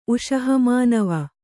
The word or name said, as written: ಉಷಃಮಾನವ